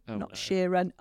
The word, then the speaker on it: Sheeran: And even I always pronounce it wrong. Is it Ed Sheeran? Sheeran.